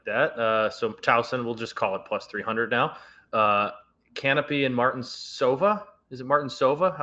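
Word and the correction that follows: Kanepi: It is a soft C, but it's Canepi.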